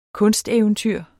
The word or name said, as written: kunsteventyr